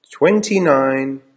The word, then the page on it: twenty nine: American English